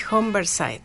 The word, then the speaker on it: Humberside: Humberside.